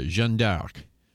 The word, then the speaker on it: Jeanne d'Arc: Joan of Arc or Jeanne d'Arc. That's how you say it in French, man. – Jeanne d'Arc. Does it say E-U-A-X?